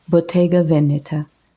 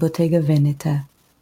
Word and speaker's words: Bottega Veneta: The first one is Spanish, and it's Balenciaga. I don't speak Spanish, but I know that's how it's supposed to be pronounced, so Balenciaga. The second one is Italian and it's Bottega Veneta. So Balenciaga and Bottega Veneta.